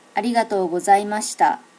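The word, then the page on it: arigatogozaimashita: native Japanese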